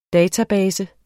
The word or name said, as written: database